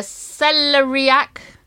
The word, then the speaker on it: celeriac: It's called a celeriac. How do you say that? – Celeriac.